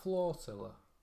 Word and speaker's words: flotilla: Is it not flotilla? – How do you say it? Like it's floating, isn't it? – F-L-O-T-I-L-L-A. – Oh, flotilla. – But like flow and then the end of tortilla.